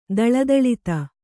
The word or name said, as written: ದಳದಳಿತ